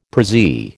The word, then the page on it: prezzie: American English